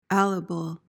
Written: alible

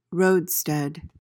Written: roadstead

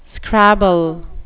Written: scrabble

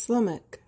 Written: slummock

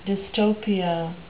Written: dystopia

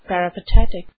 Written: peripatetic

